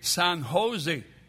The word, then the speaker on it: San Jose: And the man corrected her, and he said, the J is pronounced like an H. We say San Jose.